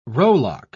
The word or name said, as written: rowlock